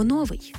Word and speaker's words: новий: В усному мовленні раз по раз чуємо «новий». Колись це слово справді мало два наголоси, але вже давно наголос на першому складі вважається ненормативним, тому слід казати «новий».